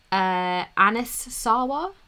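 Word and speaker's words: Anas Sarwar: So, yes, this would be the appointment of Anas Sawa.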